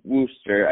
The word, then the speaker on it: Worster: I mean, R- R- Rollie Worster,